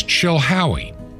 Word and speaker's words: Chilhowie: The city is Chilhowee. P.O. Box 510, Chilhowee, which is spelled C-H-I-L-H-O-W-I-E. Chilhowee, Virginia.